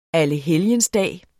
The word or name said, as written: allehelgensdag